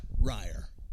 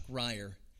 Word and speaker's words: Ryor: My name is Chuck Ryer." Now, it's spelled R-Y-O-R, and I can verify, and you can too.